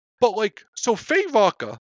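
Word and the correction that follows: Feyd-Rautha: Is that his name? What is it? Rotha? – Faye Rotha. – Faye Rotha. Okay.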